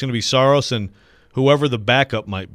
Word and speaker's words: Saros: Look, I went last year off of the official pronunciation guide that the Predators sent out. And in that, it said Saros.